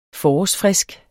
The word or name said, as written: forårsfrisk